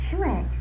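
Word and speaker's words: sumac: Shumac. – That's it. Must be the way it's pronounced. Everybody calls it shumac around here. – It's S-U. Now, I well, with sugar, it's S-U, too.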